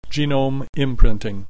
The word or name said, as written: genome imprinting